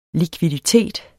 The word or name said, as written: likviditet